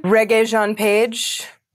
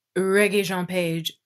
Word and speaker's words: Regé-Jean Page: Regé-Jean Page. – Regé-Jean Page.